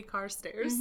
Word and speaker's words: Carstairs: Is that how you pronounce it? I don't know. – How's it spelled? – Carstairs. – Carstairs. Yep. – Yeah. – Yep. It's literally Carstairs.